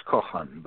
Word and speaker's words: Kochan: Is it Kochan with a silent C, or do you pronounce the C? It's it's Kochan, but what you said is fine.